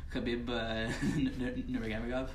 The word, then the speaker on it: Khabib Nurmagomedov: Like the people from Siberia, from the Caucasus, like Khabib Nurmagomedov. – Yeah, you got his name right. Don't worry, you said it right.